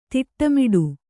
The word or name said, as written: ತಿಟ್ಟಮಿಡು